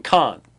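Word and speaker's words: Khan (כן): it says, in case you're wondering why he keeps saying Khan, that's how they pronounce the Hebrew term for yes.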